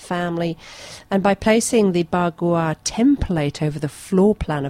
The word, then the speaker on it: bagua: The most common being ba gua. Do you think I've pronounced that right? Bagua. – Sounds about right. – B-A-G-U-A.